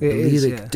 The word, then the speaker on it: Balearic: It's a big hit in Balearic. Is that how you say it? – Balearic discos.